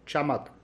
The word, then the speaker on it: Chamakh: I kept 2.5 million in the bank for Chamakh, that's how you pronounce it.